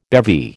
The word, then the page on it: bevy: American English